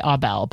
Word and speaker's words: Aubel: Jackie Ray Aubel, but that's Aubel.